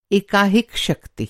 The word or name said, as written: एकाहिक